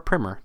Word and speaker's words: primer: Smallmouth, a Fly Gear Primer, or Primer, depending on your preferred pronunciation.